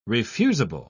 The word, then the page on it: refusable: British English